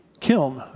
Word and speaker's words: kiln: It says, "The Lord said to Moses and Aaron, take for yourselves handfuls of soot from the kiln." That's, how do you say it? Kiln. There we go. Kiln. I guess I put two syllables in there.